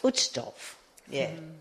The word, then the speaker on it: Uchtdorf: I knew you were going to ask me just because I don't know if I pronounce it correctly. Elder Uchtdorf. – Uchtdorf. – Uchtdorf? – Uchtdorf, yeah. – Is that correct? – That's correct for us.